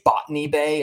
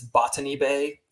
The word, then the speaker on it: Botany Bay: And they say Botany Bay is how they say it in the song, but it's Botany Bay, like a botanist.